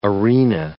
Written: arena

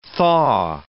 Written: thaw

